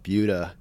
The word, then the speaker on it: Buda: Because I know I have a friend that's out in one of Austin's satellite towns called Buda. It's spelled Buddha, but it's Buda.